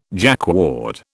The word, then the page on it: Jacquard: British English